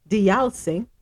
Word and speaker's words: Deyalsingh: Minister of Health Terrence Dayal Singh, Deyalsingh repronounce that. D E Y A L. Dial, say, okay.